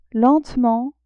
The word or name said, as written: lentement